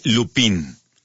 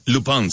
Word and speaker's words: Lupin: Se escribe Lupin. Así como un lupito chiquito, Lupin. Oh, bueno, así se llama. Lupin se pronuncia.